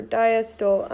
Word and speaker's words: diastole: Diastole or diastole, I need to just know real quick how to pronounce it.